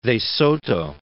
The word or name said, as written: Soto, de